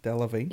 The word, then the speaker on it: Delevingne: What would you think of Cara Delevingne? Am I saying that right? – Mm-hmm. – Cara Delevingne? Delevingne? – Yeah.